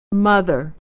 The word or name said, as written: mother